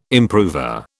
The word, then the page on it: improver: British English